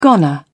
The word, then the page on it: goner: British English